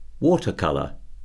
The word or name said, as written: watercolour